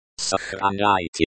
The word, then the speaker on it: сохраняйте: The stress in сохраняйте falls on the third syllable.